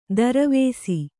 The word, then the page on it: ತಿರುವಿಸು: tiruvisu